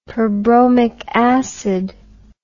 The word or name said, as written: perbromic acid